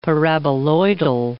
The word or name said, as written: paraboloidal